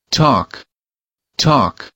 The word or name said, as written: talk